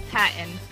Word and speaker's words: Patton: Chelsea Patton. Last name is spelled P-A-T-T-O-N.